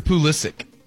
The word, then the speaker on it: Pulisic: Do you say Christian Pulisic or Pulisic? Like, what's the correct pronunciation? Pulisic? – I've heard Pulisic. – Pulisic? – Yeah, Christian Pulisic. – But there's no ch on the end of it? – I don't think so.